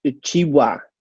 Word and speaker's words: Achiuwa: All right, Precious Achiuwa.